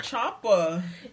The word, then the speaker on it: Ciampa: I say Ciampa. – Yeah, it's Italian. The C-I makes a C-H sound, so it's Ciampa.